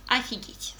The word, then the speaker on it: офигеть: Произносится именно так «офигеть» с «а», хотя пишется вначале с «о» – «офигеть».